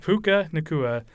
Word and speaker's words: Puka Nacua: But that doesn't mean the Rams didn't have people who stepped up from rookie Puka Nacua. Sorry, Pookie. Maybe that's his nickname or something. I don't know. – Absolutely butchered his name. Yeah, my bad. – Pookie. – Puka Nacua was tearing up the Niners defense,